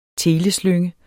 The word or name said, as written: teleslynge